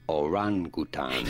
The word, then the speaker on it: orangutan: Want to stress on the second syllable, the ran syllable. Orangutan.